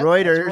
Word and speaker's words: Reuters: Israel berates New York Times, CNN, Reuters. Did I say it right this time? – Yep, Reuters, Reuters and AP over Hamas attack photographers.